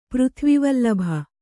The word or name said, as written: ಪೃಥ್ವಿವಲ್ಲಭ